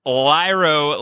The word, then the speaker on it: Lyro: Lyro, like Cairo, wearing orange again.